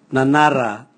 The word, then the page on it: nanara: Raga